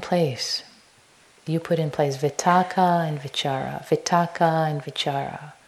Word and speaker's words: vitakka: So for those who might be interested in the Pali words, vitaka, V-I-T-A-K-K-A is how it's spelled. Vichara is V-I-C-A-R-A.